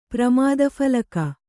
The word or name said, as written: ಪ್ರಮಾದಫಲಕ